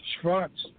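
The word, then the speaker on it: Schwarz: It was designed to be a showcase fight and a mismatch because Tom, his name is pronounced Schwartz, the German pronunciation, which I tried to get, you know, on the conference call for the media.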